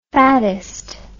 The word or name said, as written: fatist